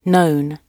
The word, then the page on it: known: British English